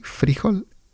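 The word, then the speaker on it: fríjol: Y una curiosidad, una diferencia entre el español de Colombia y el español de México es la palabra frijol, que en Colombia se pronuncia frijol con el acento en la I, frijol, y también dicen frijoles, mientras que en México decimos frijoles o frijol, frijoles, frijol, y en Colombia frijol o frijoles.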